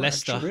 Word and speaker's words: Leicester: Leicester.